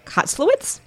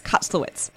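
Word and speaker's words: Kotlowitz: I'm calling him K because his last name is hard for me to pronounce, but I think it's Kotzlowitz. Yeah, Alex Kotzlowitz.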